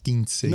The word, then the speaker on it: quinceañera: Quinceañera.